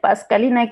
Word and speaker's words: Pascalinah: I'm on Twitter at it's at Pascalina K. My name is spelled as PASCALINAH.